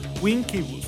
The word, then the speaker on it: Vincebus: Y que en realidad Vincebus se pronuncia algo así como Winkebus o Winkebus, algo así, ¿no? Winkebus Eruptum. Pues este álbum fue todo un éxito, tanto comercial como con la crítica, ¿no?